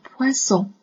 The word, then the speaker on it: poisson: 读 poisson。这里的一个组合，刚刚我们见过了哈，读哇哇。 o n 的组合呢，读。嗡嗡，好了，我们拼在一起呢，就是 poisson poisson doré doré de o a 拼在一起 doré doré un poisson doré 小金鱼 un poisson doré 好，再接下来。